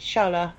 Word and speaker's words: Shaalaa: Yeah, it's Shala. Shala of Avalon.